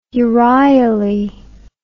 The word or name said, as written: euryale